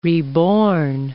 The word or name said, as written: reborn